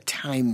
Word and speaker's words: thyme: Why is it called, why do we call it thyme when it's T-H-Y-M-E? – It should be thyme.